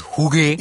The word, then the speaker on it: hygge: So, like, for example, in Denmark, they have something called H-Y-G-G-E, pronounced as hygge.